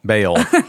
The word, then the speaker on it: Baal: Or if you're from the south, it's Baal. – Baal. Well, and that's actually how it's pronounced in Babylon.